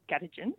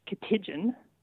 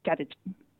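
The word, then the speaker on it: Katitjin: So Grongrol with the emphasis on the first syllable, and Gadigin, again, with the emphasis on the first syllable. So that second word, it's not, for instance, something like Katijin. It's Gadigin with the first syllable getting the stress.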